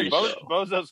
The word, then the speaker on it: Bozo: Yeah, Bozo's correct. I just misheard you, and I'm sorry. – Doesn't I don't think you're putting enough emphasis on the first O.